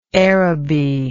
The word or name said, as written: Aerobee